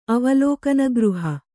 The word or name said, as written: ಅವಲೋಕನಗೃಹ